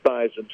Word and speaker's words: Theismann: Our public relations director, Roger Valdeseri, suggested the change of the pronunciation of my last name from Theismann to Theismann to rhyme with the trophy.